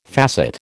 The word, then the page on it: facet: American English